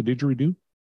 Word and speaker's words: didgeridoo: Do you have any facts about the didgeridoo? – Yeah, it rhymes with Zubily Zoo.